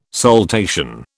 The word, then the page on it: saltation: British English